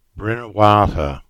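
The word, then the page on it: Bynnerwartha: Cornish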